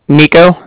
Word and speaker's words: Niko: My name's Nico. That's spelled N-I-K-O. And then the Bank of Cardiff is spelled C-A-R-D-I-F-F, as in Frank, Frank.